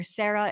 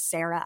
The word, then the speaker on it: Sara: I often have people ask me like, oh, how do you say your name? Is it Sara or Sarah? And I'm sure there's some people who rolled their eyes when I introduced myself at the top saying my name is Sara Alfageeh. But I do very much feel like I am Sara and Sara at the same time. I see no difference between the two.